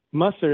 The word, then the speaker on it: Musser: Musser is my last name. It's pronounced similar to the word mustard, and I'm told it originated in Germany.